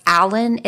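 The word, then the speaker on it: Allyn: However, 2 years after Ed was born, his youngest sister, I think her name is pronounced Allen. It's A-L-L-Y-N.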